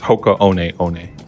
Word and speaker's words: Hoka One One: I got to pronounce the shoe brand because I did mispronounce it publicly and I want to right that wrong. So it's Hoka One One.